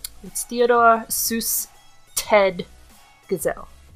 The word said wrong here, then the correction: Geisel: How is it spelt? – G-E-I-S-E-L. – Geisel.